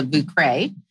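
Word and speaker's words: Vieux Carré: and it's called the Vucre. But it's spelled V as in like Via, I-E-U-X, and then C-A-R-R-E.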